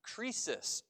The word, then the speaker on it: krisis: The word judgment in Greek is actually pronounced krisis or crisis.